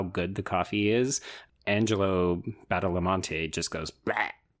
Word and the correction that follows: Badalamenti: Yeah, I think it's Badalamenti, but it doesn't really matter. You were really close.